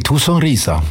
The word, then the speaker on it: Sonrisa: Nee, gar nicht. Nee, Sonrisa. Schon hast du schön gesagt. Du hast ein weiches Sonrisa.